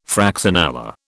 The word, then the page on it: fraxinella: British English